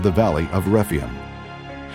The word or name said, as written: Rephaim